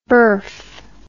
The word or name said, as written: birf